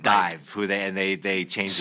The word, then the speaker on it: DIIV: It's DIIV, D-I-I-V. It was called, you spell it the regular way, but it's after the Nirvana song.